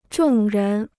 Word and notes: 众人: zhòng rén

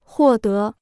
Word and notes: 获得: huò dé

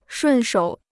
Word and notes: 顺手: shùn shǒu

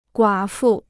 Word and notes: 寡妇: guǎ fu

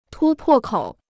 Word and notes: 突破口: tū pò kǒu

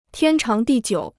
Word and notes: 天长地久: tiān cháng dì jiǔ

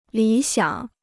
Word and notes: 理想: lǐ xiǎng

